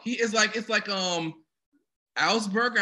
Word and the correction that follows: Asperger's: It's I think it's Asperger's.